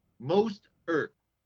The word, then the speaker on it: Mostert: The kid, the replacement, by the way, his name is Most Ert. I couldn't pronounce his name if you gave me a dollar. Mostert. And I will keep pronouncing it wrong.